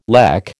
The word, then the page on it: lech: American English